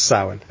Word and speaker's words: Samhain: It's one of those where all the consonants blend together. It's pronounced Samhain. Anyways.